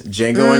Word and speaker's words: Django: Jackson in that fucking movie, The Django Unchained, which you don't pronounce the fucking J. Nobody talks about. But regardless, Django Unchained.